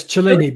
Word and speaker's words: Cellini: Lisa even said, is it Cellini? Is that how you say it? Is that my Italian? – Yes, Cellini.